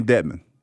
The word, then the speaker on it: Dedmon: And it's Dedmon. Did I say his name right? Dwayne Dedmon. Yeah, I said it.